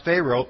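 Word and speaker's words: phero (φέρω): The Greek word is pronounced phero, P-H-E-R-O, and it's a nautical term,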